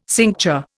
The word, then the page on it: cincture: Indian